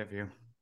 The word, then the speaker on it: Vue: Dave said, "We've had YouTube TV ever since PlayStation Vue was discontinued. Uh, is that how you, that's how you say it? Vue, right? – Yeah, Vue.